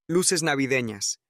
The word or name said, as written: luces navideñas